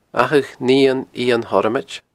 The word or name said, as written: Achadh Nighean Iain Thormoid